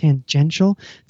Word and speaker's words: tangential: tangential. Did I pronounce that correctly? – Yeah, no, you got it. – Tangential to the main sort of dream, if you will, of travel.